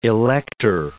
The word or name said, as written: elector